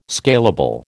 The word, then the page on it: scalable: American English